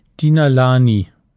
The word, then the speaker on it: Denalane: Spricht man das so aus? Aufs Ohr, nicht im, im Mund. Joy Denalani. Ich glaube schon. Wie denn sonst? Äh, das versuche ich raus. Dina Lani, sagt die Lautschrift Dinalani?